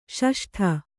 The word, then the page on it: ಷಷ್ಠ: Kannada